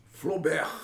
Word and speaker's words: Flaubert: Flaubert.